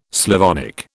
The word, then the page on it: Slavonic: British English